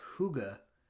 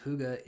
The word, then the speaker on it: Hygge: It's called The Little Book of Hygge, which is spelled Hygge.